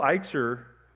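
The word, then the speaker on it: echsar: It's E-C-H-S-A-R. It's pronounced eichser. And lo eichser literally translates to "I shall not lack."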